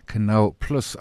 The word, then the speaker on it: Canal Plus: Currently, what, trading sort of low 90s on that 105 offer from Canal Plus, I was told is how you pronounce it.